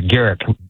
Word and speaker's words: Garrick: That's a Garrick with a hard G.